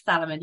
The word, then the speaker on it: Salomon: I have one from Salomon, if that's how you pronounce it. Yeah, Salomon, yeah.